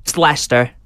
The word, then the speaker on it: Leicester: I must say, it's Leicester. That's what I wanted to say, because I know the Americans, if they Google this case, Leicester, Leicester.